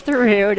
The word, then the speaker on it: Thoreau: Theroux, spelled Thoreau. – Spelled Thoreau, pronounced Theroux.